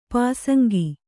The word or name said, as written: ಪಾಸಂಗ